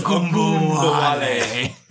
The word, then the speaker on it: Ogunbowale: His last name is Ogunbowale.